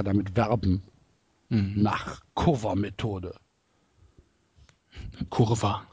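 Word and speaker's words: Coerver: Curver geschrieben, Kurva mhm. ausgesprochen, weil es ja holländisch ist.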